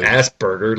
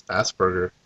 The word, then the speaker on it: Asperger: It's not like, Asperger, literally. It's not like you're shitting a burger. – It's Asperger.